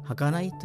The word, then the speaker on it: 儚い: 人の夢と書いて儚いと読みます